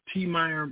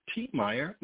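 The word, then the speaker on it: Tiemeyer: Meyer and Tasila McCain, or just put my name, T. Meyer McCain. That's spelled T-I-E-M-E-Y-E-R. T. Meyer McCain, M-C-C-A-I-N.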